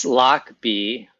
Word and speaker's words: Lochby: And so I said, it's Lochby, like a lock with a combination lock, and then B, like the animal B.